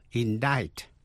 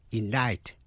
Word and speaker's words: indict: Và cuối cùng là C, câm, tức là không phát âm, trong những chữ như indict, I-N-D-I-C-T, indict, indict, truy tố.